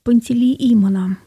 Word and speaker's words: Пантелеимон: Завтра день великомученика и целителя Пантелеимона.